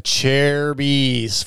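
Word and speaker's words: Acerbis: That's how you pronounce it, boys. Cherubies.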